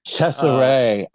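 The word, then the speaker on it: Cesare: Chesseray. I learned that that's how it's pronounced. – Oh, Chesseray. Okay. Chesseray. – I thought it was Caesar too. I always thought it was Caesar.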